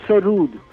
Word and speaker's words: sarod: And in fact, the the word sarod is a Persian word. Actual pronunciation is sarud. S A R double O D, Achha.